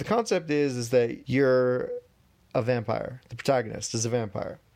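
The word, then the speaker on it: Vampyr: So it's Vampire, but they took out the E at the end and replaced the I with a Y. So it's like Vampire, but you know, the end is pronounced like pyre instead of whatever.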